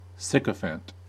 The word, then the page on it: sycophant: American English